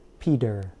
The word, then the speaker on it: Peter: I pronounced the T in the middle of the name more like a D sound. Peter. In general, when a T is between two vowels, Americans often pronounce it like a fast D sound.